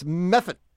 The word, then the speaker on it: Medford: We knew you weren't originally from Medford because you pronounced it incorrectly. – Ah, that's right. It should be Meffa. – It's Meffet. Meffet. M-E-F-F-I something. – Okay. – It's either a Meffet. D or a T or both. – Gotcha. – I think it's I-D-T. – Yeah, M-E-F-F-I-D-T.